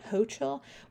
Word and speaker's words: Hochul: How do you pronounce it? Hochul.